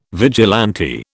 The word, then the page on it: vigilante: British English